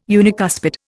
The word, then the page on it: unicuspid: Indian